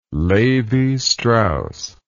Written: Lévi-Strauss

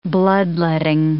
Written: bloodletting

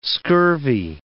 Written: scurvy

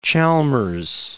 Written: Chalmers

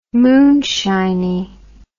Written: moonshiny

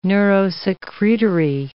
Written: neurosecretory

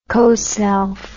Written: coself

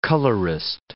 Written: colorist

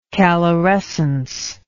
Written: calorescent

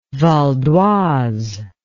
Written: Val-d'Oise

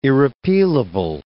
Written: irrepealable

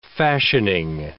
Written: fashioning

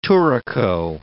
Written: touraco

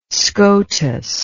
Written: SCOTUS

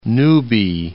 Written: newbie

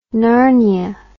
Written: narnia

